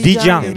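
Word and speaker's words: 丽江: 그런 다음에 또 바로 인근에 있는 버스로 한 3시간이면 가는 곳인데 리장이라고 하는 곳이 리장. 리장. 리장. 아, 리장. 아, 많이 들어봤죠. – 네. 아주 유명하죠. 네. 한국어 발음 려강입니다. 려강.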